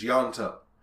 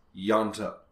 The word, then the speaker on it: Ianto: It's I-O-A... – Yeah. Okay. – I-N, I think. – Yeah. – And so in Welsh, if you follow an I with like an O or an A, it's ya. – Oh, okay. – So, like, for example, on Torchwood, there's Yanto. His name is spelled I-A-N-T-O. Yanto.